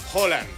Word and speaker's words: Haaland: Bueno, Alfredo González, comentarista de Fórmula Deportes, ¿cómo se pronuncia? – Holland. – Es con O. – Y es rápido, es Erling Haaland.